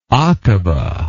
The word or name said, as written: akaba